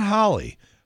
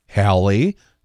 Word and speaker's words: Halle: It's not Holly. Holly is one name. Halle is a different name. And it's spelled different. And it's pronounced different.